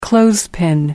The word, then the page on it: clothespin: British English